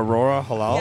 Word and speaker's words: Aurora Halal: She's from Italy, and Halala, or how you say her name? – I'm not sure. Oh, or Aurora Halal? – Yeah, genau.